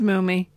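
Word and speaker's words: Mumy: But then this report they kept saying, I said, it is Mummy. That mm-hmm. that is the correct pronunciation of it.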